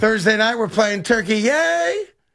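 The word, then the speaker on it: Türkiye: T-U-R-K. – Yeah, that's Turkey. Yay! – T-U-R-K-I-Y-E. – Turkey. Yay! And that's how you have to pronounce it now, too.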